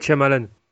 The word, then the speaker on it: Shyamalan: M. Night Shyamalan. C'est ça? – Ouais, c'est ça.